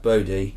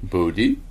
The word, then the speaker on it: Bodie: He's named Body, but I'm sure it's pronounced Bodie. – Bodie.